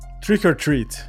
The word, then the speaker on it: trick or treat: Esse filme chama Trick or Treat, o threat. Como é que fala? – Trick or treat.